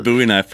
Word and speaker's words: Bowie knife: I like how you said Bowie knife, pronounced it correctly too.